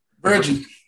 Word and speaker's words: Virgie: Yeah, at uh, Is, is it pronounced Verge? – Virgie. Virgie Binford, thank you.